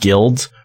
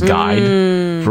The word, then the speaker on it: guild: That's one of those things that I learned that word, guild, from reading. And I thought it was guide for a long time. And I had to relearn how to pronounce that word, because I don't know why I didn't see the L. I think it's the I-L combo.